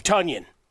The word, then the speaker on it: Tonyan: Rodgers' arm and the deployment of new emerging weapons, as well as the return of Tonyan. Yes, it rhymes with Bunyan.